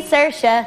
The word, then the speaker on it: Saoirse: It's sh instead of s, instead of e, simple as can be, see Saoirse.